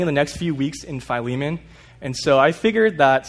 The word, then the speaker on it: Philemon: It's pronounced Philemon, not Philemon.